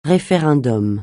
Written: référendum

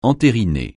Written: entériner

